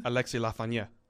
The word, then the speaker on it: Lafrenière: That pronunciation just blows everyone else away. Say that again. – Alexis Lafrenière. – Lafrenière. – Lafrenière. – I've been saying Lafrenière. Yeah. – Well, that's wrong.